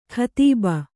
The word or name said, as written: ಕದುಬು